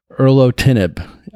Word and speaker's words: erlotinib: It's a trial that's looking at what's called high pulse dosed, hope I pronounce this right, erlotinib. I'll spell that E-R-L-O-T-I-N-I-B.